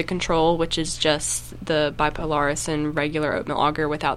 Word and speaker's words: Bipolaris: So we're testing four fungicides with bipolaris.